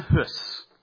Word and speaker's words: Hus: Second man that I want to introduce you to, Jan Hus. It's spelled H-U-S. It's Czech, which is why it's not pronounced quite the way that we might expect.